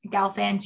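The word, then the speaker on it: Gelfand: So for the like two listeners that don't know me, my name is spelled K-I-E-R-S-T-E-N. And then there's a dot, Galfand, G-E-L, F as in Frank, A-N-D as in dog.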